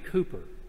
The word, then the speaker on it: Cowper: And it involves a man named William Cooper. We pronounce it Cooper, but it's spelled C-O-W-P-E-R, like Cowper.